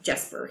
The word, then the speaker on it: Jesper: Hischier, Pavel Zacha, Jesper Bratt. I guess Jesper, he's a hard J guy.